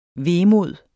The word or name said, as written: VEMODEN